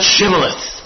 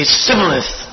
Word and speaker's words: Shibboleth: They were fighting among themselves, and the one tribe, they couldn't pronounce Shibboleth. They always say Sibboleth.